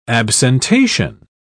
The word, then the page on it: absentation: British English